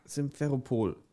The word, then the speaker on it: Simferopol: Wie schreibt man das? S-I-M? – Äh, so wie Sims? S-I-M. S-I-M-F-E-R-O-P-O-L. Simferopol.